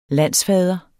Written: landsfader

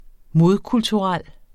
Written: modkulturel

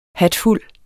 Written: hatfuld

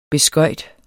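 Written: beskøjt

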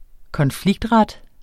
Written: konfliktret

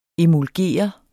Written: EMULGER

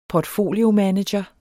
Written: portfoliomanager

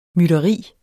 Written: mytteri

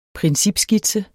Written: principskitse

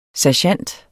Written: sergent

